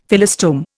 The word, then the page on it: Phyllostome: Indian